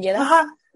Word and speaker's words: Jeddah: Sí, se pronuncia Jeddah, ¿verdad? Sí, bueno, yo lo pronuncio Jeddah.